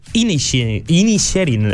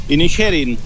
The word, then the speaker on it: Inisherin: Comencem per The Banshees of Inisherin. No sé si ho he pronunciat bé. – Sí, més o menys, suposo. Aquí l'hem traduït. A mi em costa molt, també. Inisherin.